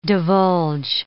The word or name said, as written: divulge